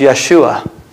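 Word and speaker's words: Yeshua (ישוע): In Hebrew, the name is pronounced Yeshua.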